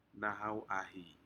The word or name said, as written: nahau ahii